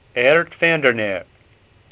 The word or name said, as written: Neer